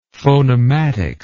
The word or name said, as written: phonematics